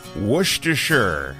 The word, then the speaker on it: Worcestershire: Worcestershire. I guess the key is to drop the first R in the word and soften the CE part.